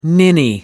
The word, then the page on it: ninny: American English